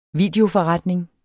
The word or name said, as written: videoforretning